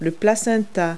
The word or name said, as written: placenta